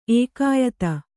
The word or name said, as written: ಏಕಾಯತ